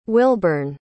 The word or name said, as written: wilburn